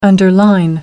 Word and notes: underline: American English

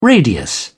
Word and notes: radius: British English

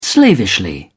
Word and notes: slavishly: British English

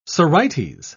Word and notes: sorites: British English

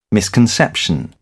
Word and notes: misconception: British English

